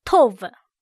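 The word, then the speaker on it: tov (טוב): 我们今天要看的字是 tov，好这个字，它是好的，读音是 tov。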